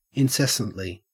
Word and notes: incessantly: Australian English

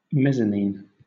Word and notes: mezzanine: British English

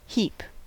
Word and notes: heap: American English

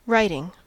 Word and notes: writing: American English

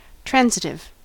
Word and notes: transitive: American English

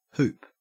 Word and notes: hoop: Australian English

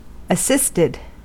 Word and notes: assisted: American English

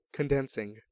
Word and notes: condensing: American English

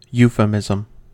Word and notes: euphemism: American English

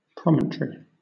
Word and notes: promontory: British English

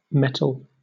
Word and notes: mettle: British English

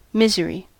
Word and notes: misery: American English

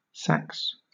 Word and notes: sax: British English

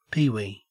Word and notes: peewee: Australian English

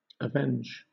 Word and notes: avenge: British English

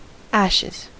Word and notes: ashes: American English

